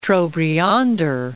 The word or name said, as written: Trobriander